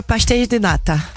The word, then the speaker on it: pastel de nata: Peut-être qu'ils demandent aux touristes de prononcer qui dira le mieux Pastel de Nata. – Ah, c'est pas mal, tu le dis bien en tout cas, ce n'est pas ça.